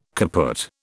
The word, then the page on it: kaput: British English